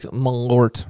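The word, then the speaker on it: Malört: It's like Malort rhymes with wart.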